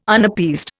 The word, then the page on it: Unappeased: Indian